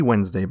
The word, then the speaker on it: Wednesday: The way I'm saying it, and I think the way it's supposed to be said in North America anyway, I feel that I would spell it W-E-N-Z-D-A-Y, Wednesday. Yet, for some kooky reason, we spell it as the following. W-E-D-N-E-S-D-A-Y. To me, that is Wednesday.